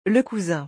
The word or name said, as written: le cousin